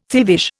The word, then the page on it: thievish: Indian